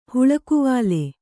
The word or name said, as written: ಹುಲ್ಲುಗಾವಲು